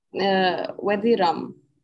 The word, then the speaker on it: Wadi Rum: Is Wadi Rum, am I pronouncing that correctly? – Wadi Rum, yeah.